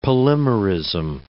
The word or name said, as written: polymerism